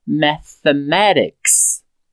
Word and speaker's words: mathematics: Na palavra inteira vai ficar mathematics. Mathematics. Outra observação é que o último ter de mathematics, nós pronunciamos com esse sonzinho meio parecido com D ou R.